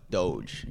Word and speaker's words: Doge: Well, I know some people say Dogecoin. Like, no, it's Doge.